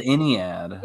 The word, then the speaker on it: Ennead: Cause especially going back and watching the first episode and how they tease the, Lord, my pronunciation, Enet, like whatever the nine gods. – Oh, the Ennead.